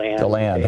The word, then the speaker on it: DeLand: Or is it DeLand, Florida? How do you say that? – DeLand.